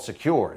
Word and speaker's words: Sekur: That's why you need a new privacy and cybersecurity application tool called Secure. It's spelled S-E-K-U-R.